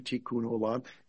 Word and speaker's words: Tikkun Olam: Well, it's pronounced, as you can see, Tikkun Olam. It's Hebrew, and it simply means to repair or heal the world.